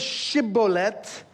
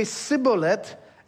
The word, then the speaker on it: Shibboleth: The word is Shibboleth. Ephraim doesn't have the sh sound, so they say Sibboleth.